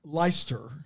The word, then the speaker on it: Leicester: How would you say that? – Leicester. – Leicester? Yeah. Yeah, that's now that I see it, it's Leicester. Leicester Square. – Leicester. – And then... – You added like two syllables.